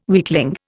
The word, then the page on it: weakling: Indian